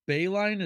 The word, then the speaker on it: Boeheim: Like, and not to mention, how many times did they go to at least the Sweet 16 or Elite Eight under, is it John Boeheim? Is that how you pronounce it? – Yep.